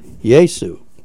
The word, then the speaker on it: Yaesu: Yaesu, that's spelled Y-A-E-S-U, Yaesu,